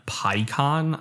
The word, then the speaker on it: Peikon: Yeah, so uh, this week it is Evan Peikon, I believe is how you pronounce his name P E I K O N.